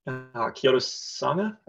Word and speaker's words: Kyoto Sanga: But I think he's got a pretty good fixture against Kyoto Sanga, I think.